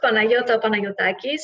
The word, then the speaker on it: Panayota Panayotakis: Uh, okay, my name is Greek. So uh, it, it's pronounced Panayota Panayotakis.